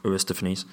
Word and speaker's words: Aristophanes: Socrates, Aristophanes. – Aristophanes. – Yeah, that's probably it.